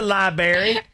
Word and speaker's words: library: It's a library. – Even adults say it, and I'm like, that's not how you say it. – There's an R in there.